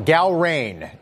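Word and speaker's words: Gallrein: Gal-Ryan is the pronunciation.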